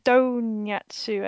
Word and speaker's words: Donyatsu: And I've just realised it's pronounced Donyatsu, as in a really bad pronunciation of donut.